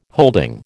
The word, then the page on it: holding: American English